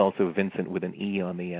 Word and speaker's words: Vincente: I have to ask you, Vincent or Vincente? – It's actually Vincent. I've heard it pronounced either way, but most typically you hear Vincent Minnelli is, I think, the correct pronunciation.